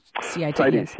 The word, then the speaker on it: CITES: This is the Convention on International Trade in Endangered Species, which is, I guess the shorthand is CITES. Is that how you say it? – CITES. – Yes. Yeah. CITES.